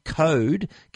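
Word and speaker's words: Qode: Belkin have got two new Code keyboard cases. That's Code spelled with a Q, Q-O-D-E.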